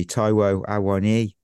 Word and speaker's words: Awoniyi: So I mean, I've gone with my birthday buddy, Taiwo Awoniyi. Yeah, look at that. I pronounced it right.